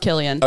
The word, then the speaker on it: Cillian: Cillian Murphy? – Killian? – I don't know. – I think Whatever. It's pronounced Killian.